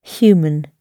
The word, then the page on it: human: British English